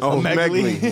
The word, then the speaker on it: Omegle: Oh, is that how you say it? Omegle? I'm saying yeah. Omegle, huh? – That's Omegle. My nigga.